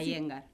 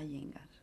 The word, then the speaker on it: Iyengar: Y bueno, y gracias a unos chicos que trajeron a la Iyengar a Posadas. – ¿Cómo es que se pronuncia? – Iyengar.